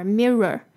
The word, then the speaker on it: mirror: 好，接下来我们来看今天的 pronunciation tips。首先，yet you gaze 这边的 gaze 的那个 a 要记得拉长音，然后那个 z 要发出来，gaze。In the bathroom mirror，这个 mirror 的 i 呢是短的 e 的音，mirror。那有些人会强调那个后面那个 r o r mirror，那有些人就是不会强调它，听起来就是一个卷舌音，mirror。就有点像